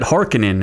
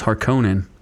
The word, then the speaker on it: Harkonnen: They mispronounced Harkonnen, so yeah. – Oh, do they really? – Oh, they called it Harkonnen, and it's not. It's Harkonnen.